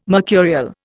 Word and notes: mercurial: Indian

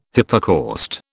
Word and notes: hypocaust: British English

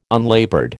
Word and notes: unlaboured: American English

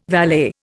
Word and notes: valet: Indian

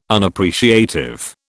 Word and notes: Unappreciative: British English

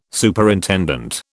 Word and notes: Superintendent: British English

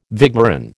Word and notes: vigneron: American English

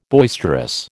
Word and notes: boisterous: American English

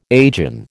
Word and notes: agin: American English

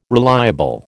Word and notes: reliable: American English

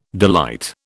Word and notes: delight: British English

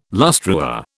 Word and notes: lustreware: British English

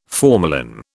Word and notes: formalin: British English

